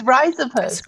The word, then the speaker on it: Rhizopus: It's rhizopus.